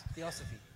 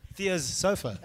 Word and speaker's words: Theosophy: Emphasis on the os. Theosophy. – Thea's sofa. No. Theosophy, okay? It has been the pursuit to literally astral travel, leave your body and take on other beings.